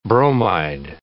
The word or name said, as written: bromide